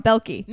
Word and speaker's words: Boehlke: Andrea, I'm going to say your name wrong, Boehlke. – No, you got it right.